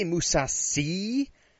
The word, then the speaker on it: Mousasi: Gegard Mousasi and Thiago Santos, who are you going to pick? – I'm going Mousasi. Did you say Mousasi or Mousashi? – I say Mousasi.